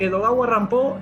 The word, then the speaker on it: Edogawa Rampo: Tiene que ver con algo que hablamos en el Zoom pasado con Edgar Allan Poe, ya que Edogawa Rampo es la forma en que el nombre Edgar Allan Poe se pronuncia en japonés.